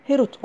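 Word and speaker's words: Herut (חרות): Atalarımızın dediği gibi, onu Harut olarak değil, Herut olarak telaffuz et.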